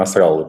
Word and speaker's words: Насраллы: подчеркну, Насраллы, прошу прощения, меня уже указывали специалисты, арабисты, что именно так надо ставить ударение.